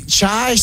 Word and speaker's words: Chai: How do you pronounce this? – Chai. – Chai, C-H-A-I, Chai. Okay.